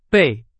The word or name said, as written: 倍